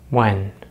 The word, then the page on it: when: American English